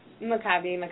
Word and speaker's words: Maccabi: Maccabi.